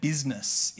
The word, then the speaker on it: business: I find it really ironic that the word business is spelt with a silent I.